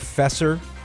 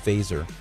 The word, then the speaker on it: Feser: If you are interested in reading an article by Edward Faeser, it looks like Fesser, F-E-S-E-R, but it's pronounced Faeser.